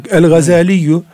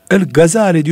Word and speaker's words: Gazali: El-Gazaliyyu. El-Gazali diyoruz ama asıl adı Gazali. Türkçe'ye şeddesiz olarak geçmiş ve galat-ı meşhur daha evla diye öyle telaffuz ediyor.